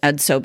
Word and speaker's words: EDSO: There are actually four, and they're referred to as EDSO, and that's pronounced EDSO, but stands for epinephrine, dopamine, serotonin, and oxytocin.